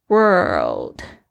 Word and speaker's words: world: 这个 what 就是什么， does the world need 就是一个疑问句，就是说这个世界， the world 就是世界，特别注意一下它的发音非常的不好发，因为你首先要这个舌头要稍微卷起来发这个 r 的声音，接着你要又要上翘要发这个 l 的声音。 world world，所以你的舌头好像在你的口腔里面就是转了一圈。